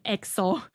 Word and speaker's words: EXO: 那这就诞生了 K-pop 历史上的第一个概念世界观玩超大的团 EXO，一不发音 XO。